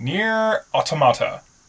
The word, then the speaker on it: Nier Automata: And I played Gravity Rush 2, and I played Nier Automata.